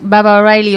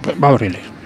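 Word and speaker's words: Baba O'Riley: Y las dos últimas, Baba O'Reilly o Who Are You? – Baba O'Reilly. – La he pronunciado súper bien, he dicho, he y me ha corregido aquí ah. sutilmente.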